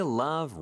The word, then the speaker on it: love: 자, 일단 love L 발음이 중요해요. 왜 중요하다고 하느냐? 리을처럼 발음하면 rub로 들릴 수가 있거든요.